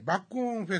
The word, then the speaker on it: 麦の音: えっと、麦の音のフェスティバルと書いて、バックオンフェスと。麦の音、はい。うん、バックオンです、ね、バックオン。